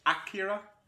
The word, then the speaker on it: Akira: It's Akira. I believe the A is pronounced.